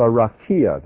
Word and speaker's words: rakia: The rakia, that's how it's pronounced.